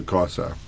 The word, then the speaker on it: CASA: So World Spine Day is brought to you by an association called CASA, if I pronounce it correct. C-A-S-A. – Yes. – Chiropractic Association of South Africa. What is it and who is a CASA or CASA?